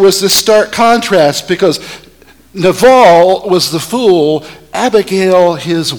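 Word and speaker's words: naval (נָבָל): Now, the word fool in Hebrew is pronounced naval.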